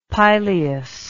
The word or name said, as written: pileous